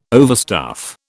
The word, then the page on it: overstuff: British English